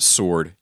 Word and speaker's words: sword: But Joe, it's pronounced sword, you idiot. – Sword. – Yeah, don't overpronounce the W.